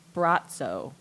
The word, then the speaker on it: Bratzo: It's pronounced Bratzo. B-R-A-T-Z-O-H is the pronunciation.